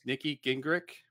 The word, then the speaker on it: Nikki Gingrich: Today, we have with us Nikki Gingrich.